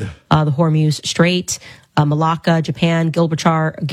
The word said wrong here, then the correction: Gibraltar: Gil, I always say that wrong. Gilbert. You guys know. Gibraltar.